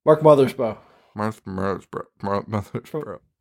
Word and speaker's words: Mothersbaugh: How do you say his name? – Mark Mothersbaugh.